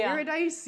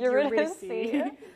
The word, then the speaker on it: Eurydice: I also had to explain how to pronounce Eurydice, because he's like, Eurydice. – Eurydice. – I was like, Eurydice.